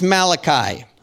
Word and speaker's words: Malachi: Some of you might know this prophet by his Italian name, Malachi, okay? If that helps you, okay, we're good with that. But in the Hebrew, it's actually pronounced Malachi, okay?